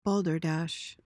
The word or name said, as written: balderdash